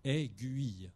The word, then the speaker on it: aiguille: le U est vraiment une lettre qui se prononce aiguille.